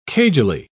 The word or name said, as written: cagily